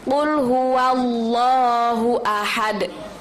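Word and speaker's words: qul huwallahu ahad (قُلْ هُوَ اللَّهُ أَحَدٌ): Kalau fathah kita bacanya wa, bukan wo. Jadi qul huwallahu ahad.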